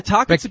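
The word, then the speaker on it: Tocchet: Is it Rick Toche? How do you pronounce that, Greg? – Yeah, Tockett's a good one.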